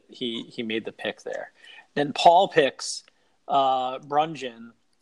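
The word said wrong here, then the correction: Bruján: who obviously Brujan. – Okay, I'm terrible pronouncing names.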